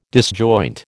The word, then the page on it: disjoint: American English